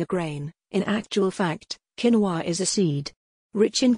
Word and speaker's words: quinoa: Quinoa may have caused a number of heated debates regarding its pronunciation, which, by the way, is quinoa, but don't let that put you off, as quinoa is considered one of the healthiest ingredients on the planet.